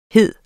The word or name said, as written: hed